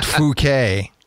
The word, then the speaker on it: Phuket: Phuket. It's pronounced Phuket.